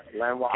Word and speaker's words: Lanois: Yeah, Lanois.